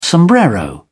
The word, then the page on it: sombrero: British English